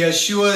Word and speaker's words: Yeshua: You'll hear me say the Yeshua sometimes. It's the proper way to say Jesus in Hebrew.